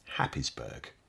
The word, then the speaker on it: Happisburgh: Our last tale takes us to Haysboro, spelt H-A-P-P-I-S-B-U-R-G-H, Happysburg. We've no idea why it's pronounced that way, it's just the way it is.